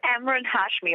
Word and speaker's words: Emraan Hashmi: It's Emraan Hashmi.